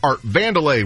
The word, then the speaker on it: Art Vandelay: George calls himself Art Vandelay when he claims to be an architect.